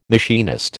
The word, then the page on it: machinist: American English